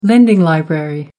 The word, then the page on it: lending library: American English